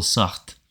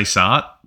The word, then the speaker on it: Sartre: I literally looked up the definition, pronunciation in French. And then I'm like, where's the English one? So I don't sound like some stupid fool. And it's probably easier to say Sartre.